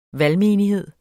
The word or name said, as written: valgmenighed